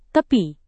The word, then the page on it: tepi: indonesian